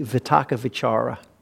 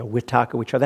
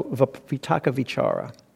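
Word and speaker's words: vitakka vicāra: Sometimes you'll hear it with a W instead of V, a vitaka vichara. That's a Burmese accent. That's how they pronounce Pali. But if you go to the international standard Pali, it's not a right or wrong, it's different pronunciations. It's a V.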